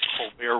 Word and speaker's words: Colbert: I've seen him, for example, on either The Daily Show or the Colbert Report, with the silent T at the end of both words.